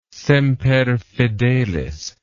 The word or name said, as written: semper fidelis